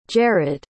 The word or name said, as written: jarrod